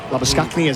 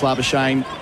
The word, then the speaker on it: Labuschagne: Yep, it's Manus Labuschagne, as I said over the loudspeaker, Manus Labuschagne, as we have been conditioned to pronouncing it.